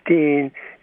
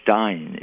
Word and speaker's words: Stein: The German word Stein, it's pronounced with a sh.